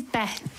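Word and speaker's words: Beith: So we finish on this, but the pronunciation of this is Beth. – Beth, as in B-E-T-H, but it's B-E-I-T-H.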